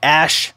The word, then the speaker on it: Asch: Now, I want to talk about something called the Ash Conformity Experiment. Ash is spelled A-S-C-H. The Ash Conformity Experiments.